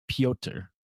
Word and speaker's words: Petr: Again, there's a UFC fighter's name is P-E-T-R and it's pronounced Pyotr. – P-P-O-P.